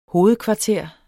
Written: hovedkvarter

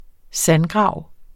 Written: sandgrav

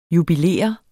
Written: JUBILERES

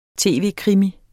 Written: tv-krimi

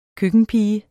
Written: køkkenpige

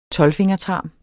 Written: TOLVFINGERTARMEN

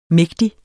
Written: mægtig

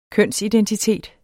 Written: kønsidentitet

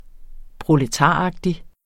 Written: proletaragtig

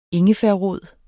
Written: ingefærrod

